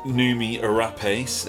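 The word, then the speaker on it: Noomi Rapace: Noomi Arapace in it. – Yeah. – Said it right. – You did. And then on Netflix, there's been a few things lately.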